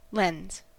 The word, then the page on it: lens: American English